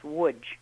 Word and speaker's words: Łódź: Yes, yes it's in English it's pronounced Lodz, in Polish it's pronounced Łódź.